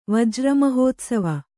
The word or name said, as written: ವಜ್ರಮಹೋತ್ಸವ